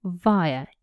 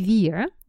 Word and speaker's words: via: Пишется V-I-A, via. Произношение может быть via, вы можете такое услышать, особенно в американском английском. Я говорю via, это британский вариант.